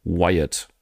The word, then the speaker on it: Wyatt: Jeremy Wyatt. Ganz langsam aussprechen. – Wie Bray Wyatt auch.